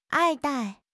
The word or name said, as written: 爱戴